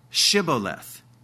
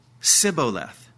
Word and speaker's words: Shibboleth: They couldn't pronounce, for some reason, they couldn't pronounce the SH of Shibboleth, which simply means a stream going by.